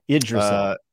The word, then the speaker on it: Yggdrasil: oh God, how do you pronounce this? I always forget. Yggdrasil's? – Idrisil. – Idrisil, there we go.